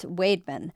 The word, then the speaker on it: Weidman: As the Morning Sun of Alma reported, a community in Isabella County that looks on paper like Weidman is actually pronounced Weidman.